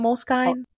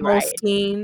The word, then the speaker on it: Moleskine: Is that how you say it, Moleskine? – I think it's Skeen, but I could be wrong.